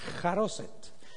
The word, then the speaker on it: charoset: It's spelled with CH, but CH in Hebrew is pronounced like charoset.